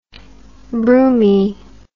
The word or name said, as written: broomie